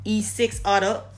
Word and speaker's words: E6utta: So definitely check out E Butter, E6utta. I mean, it's really E6utta. So it's E a six U T T A. So really six butter, but the B is silent, so that boy put a six.